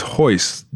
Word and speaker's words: Royce: Royce is Hoist. The way you pronounce the R in Portuguese is like an H. – We're in America. – You said it's Hoist Gracie. It's R-O-Y-C-E.